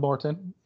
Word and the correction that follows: Morton: It's just pronounced Martin. So it's okay.